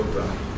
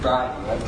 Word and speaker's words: Brahe: So at any rate, Brahe, whatever. What did you say? – Is it Tycho Brahe? – Brahe, okay. So he made very careful observations of the planets.